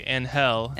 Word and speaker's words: Angel: Well, I think you say Angel. – Angel, yeah. – Angel is how you pronounce it in Spanish.